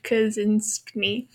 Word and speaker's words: Kaczyński: That's a very butchered pronunciation of Kaczyński, but that is what we're going for. – It sounds like something I think it's very Americanized.